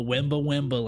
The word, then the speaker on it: Wemba Wemba: And I'll tell you, it comes from the Wemba Wemba language. – Okay. – And it's spelled exactly how it sounds. – Yeah. – W-E-M-B-A-W-E-M-B-A. Wemba Wemba.